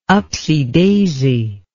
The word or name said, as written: upsy daisy